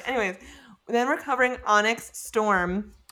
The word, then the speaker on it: Onyx: You always say Onks, and I'm like, what? – Onyx? – What is Onks? Onyx, yes.